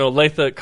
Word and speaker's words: Olathe: You're from Olathe? – Yeah. – Is that how you say it? – Olathe, Kansas. – Okay, welcome. – Knew how to say that because there's an Olathe, Colorado.